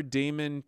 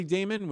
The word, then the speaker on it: daemon: There's something in you articulating your daemon to me that allows me to understand my daemon better. And so that's kind of my ultimate quest. And I think I'm intensely curious about my daemon, which makes me intensely curious about your daemon.